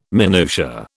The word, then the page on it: minutia: British English